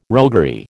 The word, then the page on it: roguery: American English